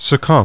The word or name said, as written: SUCCUMB